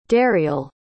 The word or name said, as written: dariel